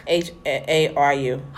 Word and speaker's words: Haru: H A R U. – Hyru. – Hyru. – Hyru. – Hyru. Like Hyru Hara Hara? – Hyru. – Like Hyru Suzumiya. Oh, yeah. Yeah. – Haru. Like Haru.